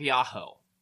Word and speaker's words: Yahoh: But on Elephantine, there was a flourishing temple of Yahoh, the Aramaic pronunciation of Yahweh, surrounded by a well-garrisoned fortress.